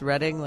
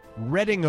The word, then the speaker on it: Reading: Is it pronounced Reading, like Reading Railroad? – You know, maybe?